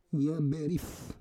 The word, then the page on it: yaberif: Roon